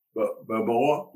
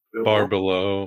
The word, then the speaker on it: Barbelo: Balboa, am I saying it right? – Barbelo.